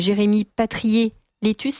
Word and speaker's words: Jérémy Patrier-Létus: À sa tête, un président dont finalement on a moins entendu parler, mais on va essayer d'en parler un peu avec vous, qui est Jérémy Patrier-Létus, c'est ça, je prononce bien. – Oui.